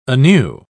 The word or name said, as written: anew